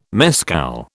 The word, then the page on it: mescal: British English